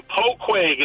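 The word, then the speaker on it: Poughquag: I'm from Poquag. It's about halfway between Poughkeepsie and the Connecticut state line in Dutchess County. – And can you spell that for us? – P P-O-U-G-H-Q-U-A-G.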